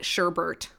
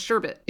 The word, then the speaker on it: sherbet: No, there's no second R. It's just sherbet.